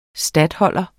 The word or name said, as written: statholder